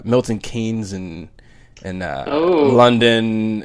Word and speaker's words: Keynes: It's named after the poet John Milton and the economist John Maynard Keynes. – Wow. – But it's pronounced Keynes. Go figure.